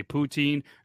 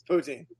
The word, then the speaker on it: poutine: How is it pronounced? – It's poutine. – Oh, thank God. – But, or, let's, well, because Toby. – Butor. – Toby loves Quebecois so much. It's poutine.